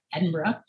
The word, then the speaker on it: Edinburgh: I got to make sure I say it right. Edinburgh.